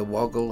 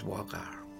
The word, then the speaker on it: Waugal: So when you hear people saying the woggle or the waggle, maybe they're not wrong, but the correct way to say it is wagar.